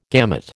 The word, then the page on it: gamut: American English